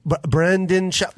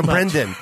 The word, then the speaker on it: Brendan: Brandon? No, Brendan.